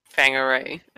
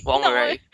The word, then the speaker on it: Whangarei: Am I saying that right? Oh my gosh, Delaney's going to kill me. – Fangarei. Wongarei. The white people up there, they say Wongarei.